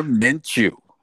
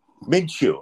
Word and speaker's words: Minshew: Gardner Minshew. – Minshew.